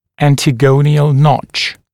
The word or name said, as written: antegonial notch